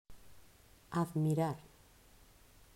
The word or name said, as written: Admirar